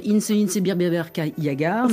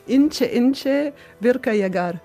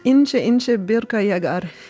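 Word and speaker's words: İnce İnce Bir Kar Yağar: Moi, je dirais Inche Inche Birbirka Yagar. – C'est pas ça. – Allez-y. – Si, c'est ça. Inche Inche Birka Yagar.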